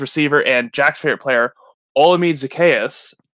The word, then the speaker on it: Olamide Zaccheaus: It's Olamide Zaccheaus, and he deserves better. And this, all my sleeper guys, Marvin Hall, Olamide Zaccheaus, you're all breaking my heart right now.